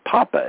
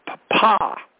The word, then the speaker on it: Papa: It's O-S-C-A-R, but it's pronounced Oscar. And Papa is not Papa, it's Papa. You're supposed to emphasize the second syllable.